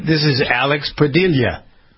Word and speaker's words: Padilla: Okay, so Jennifer, Alex Padilla, the new council.